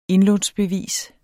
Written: indlånsbevis

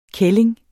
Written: kælling

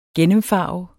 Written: gennemfarve

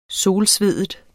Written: solsveden

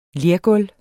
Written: lergulv

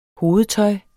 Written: hovedtøj

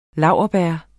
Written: laurbær

